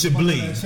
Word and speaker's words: Chablis: He thought it was Chablis, but it's Chablis. – Chablis. – Right. So, Von Dangier. – Von Dangier. – Chablis. – Right, we drinking some Chablis.